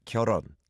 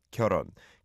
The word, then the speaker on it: 결혼: Yeah, the two syllables are 결 and 혼, but when it goes together, the hit is kind of weak, right? 결혼, 결혼, 결혼은 선택.